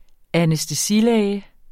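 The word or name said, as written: ANÆSTESILÆGEN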